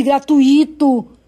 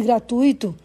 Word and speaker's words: gratuito: Há ainda quem não consiga pronunciar bem palavras ao estilo de gratuito em vez de gratuito.